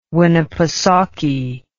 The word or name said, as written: winnepesaukee